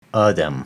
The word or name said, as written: Adam